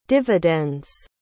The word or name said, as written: dividend's